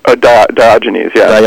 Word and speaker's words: Diogenes: And I'm going to mispronounce this, I'm sure. Diogenes of Sinope? – Diogenes, yeah. – Diogenes, yeah. Mm-hmm.